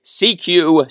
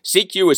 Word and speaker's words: SECU: CQ is spelled S-E-C-U, all caps.